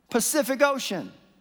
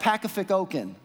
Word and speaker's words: Pacific Ocean: And then we came to this word, Pacific Ocean. This is maddening. Because in these two words, there are three C's, and they're all pronounced differently.